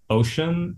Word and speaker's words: Ocin: Ocean is one. Shout out to Courtney Chu. O It's spelled OCIN.